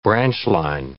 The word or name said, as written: branch line